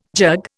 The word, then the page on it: jug: Indian